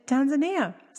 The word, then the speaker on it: Tanzania: And I asked them, I was like, guys, how do you say it? And they said, Tanzania. So I went back to educate South Africans. – Wow, because I'm the same. I've always called it Tanzania.